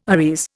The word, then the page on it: arrears: Indian